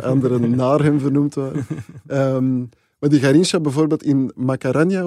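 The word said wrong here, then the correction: Maracanã: of hoe moet je dat uitspreken? – Maracana.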